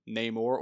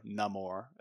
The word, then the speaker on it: Namor: Tenoch Huerta, I believe that's how it's pronounced, as Namor or Namor, as they say.